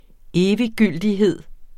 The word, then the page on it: eviggyldighed: ˈeːviˌgylˀdiˌheðˀ; -ˌgyldi-